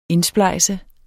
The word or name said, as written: indsplejse